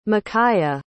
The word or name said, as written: makyah